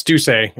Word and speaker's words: Doucet: It's Doucet. It's French.